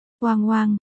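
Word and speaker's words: Oang Oang (旺旺): Vượng Vượng nguyên văn là Oang Oang, đọc là Oang Oang, đồng âm với chữ Vọng trong tên của Từ Vọng, đồng thời cũng là tên của một hãng đồ ăn vặt rất nổi tiếng.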